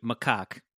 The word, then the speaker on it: macaque: It feels sad for the monkey, which is pronounced, I just listened to it, macaque.